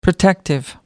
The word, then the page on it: protective: American English